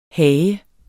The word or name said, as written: HAGERNES